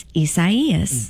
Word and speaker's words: Isaias: I thought Isaias. But instead, apparently, Isaias. – It's a Hispanic name, mm-hmm. which means that it's pronounced differently, and it's Isaias.